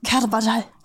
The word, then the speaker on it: Carvajal: Viertplatzierter war der Postbote Felix Carvajal. – Carvajal? – Nee, es ist ein spanisch ausgesprochener Name, Leute, sorry. – Da musst du es anders betonen. Carvajal.